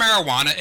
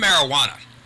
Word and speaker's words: marijuana: Now, the term marijuana, again, it does look like a Spanish word, as the J is pronounced like an H.